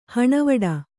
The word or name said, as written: ಹಣವಡ